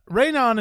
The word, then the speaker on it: Rhiannon: We had a little discussion and then we had Rhiannon. – We had a Dutch woman tell us how to say it. – Rhiannon. – Rain On it is.